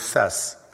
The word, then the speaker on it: Fess: As it happens, the word Fess, pronounced like the actor's given name, Fess, means buttock in French.